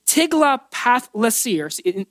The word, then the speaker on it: Tiglath-Pileser: It's Tiglath-Pileser.